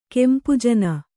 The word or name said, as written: ಕೆಂಪುಜನ